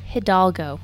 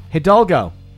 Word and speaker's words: Hidalgo: Hidalgo. – Hidalgo.